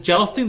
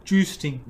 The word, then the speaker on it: jousting: Is it jousting? Do you pronounce it jousting or juicing? – Jousting.